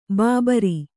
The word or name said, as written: ಭೂಭಾರ